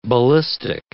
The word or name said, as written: ballistic